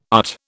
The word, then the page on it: UT: British English